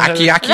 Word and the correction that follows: acai: Oh, acai berry.